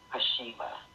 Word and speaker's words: Khoshaba: Okay, let's go to the article written by Dr. Deborah Hashima. That's K, and I think the K is silent, K-H-O-S-H-A-B-A.